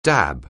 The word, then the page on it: dab: anglais